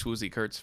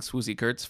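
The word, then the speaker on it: Swoosie Kurtz: You are a huge Swoozy Kurtz fan. – I can't believe you pronounced it right. – Did you know that me saying Swoozy Kurtz 5 times is the most it's been said since 1996? – That's true.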